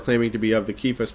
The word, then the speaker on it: Cephas: Now I've heard plenty of people pronounce that Cephas, but there is no C in Greek, and this C would represent a K in the Greek, so it'd be Cephas, at least in the Greek way of saying it.